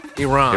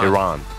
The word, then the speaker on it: Iran: Get it pronounced correctly. – Yeah. Iran. – Iran. – Iranian. Yes. – Not Iran and Iranian.